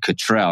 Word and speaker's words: Cottrell: Her name is Susan Cottrell.